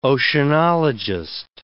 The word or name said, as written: oceanologist